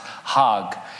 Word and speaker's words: Hajj: The annual pilgrimage to Mecca is spelled H-A-J-J. I would say it Hajj, but it's pronounced Hag.